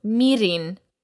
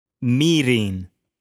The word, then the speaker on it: mirin: And actually remember that the emphasis goes on the first word, so mirin. – Mirin.